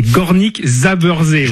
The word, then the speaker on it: Górnik Zabrze: C'est Górnik Zabrze, le vainqueur de la dernière Coupe de Pologne.